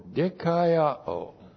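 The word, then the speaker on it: dikaiō (δικαιόω): D-I-K-A-I-O, long O, dikaiō.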